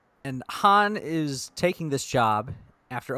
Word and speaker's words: Han: I love that you pronounce it Han like Lando. – Yeah, Han.